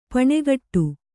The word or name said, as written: ಪೆಣಗಾಟ